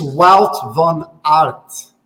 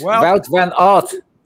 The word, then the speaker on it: Wout van Aert: John, it's Wout van Aert. – That's it. Wout van Aert, John.